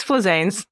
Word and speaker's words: Flizanes: No, but that's okay. It's Flizanes.